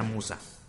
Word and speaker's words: Muxa: el, hace, hace un par de meses me tocó platicar con el dueño y le pregunté cómo se pronunciaba y, y creo que me dijo que era Musa con s Sí, según sí.